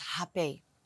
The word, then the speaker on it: Rapéh: I say it in a very Western way, because actually the way they pronounce it is Rapéh. It comes from Brazil, and in Brazil they don't pronounce the R, so as like we do in the Netherlands.